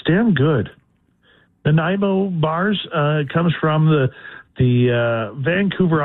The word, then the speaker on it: Nanaimo: N-A-N-A-I-M as in Mary, O, Nanaimo.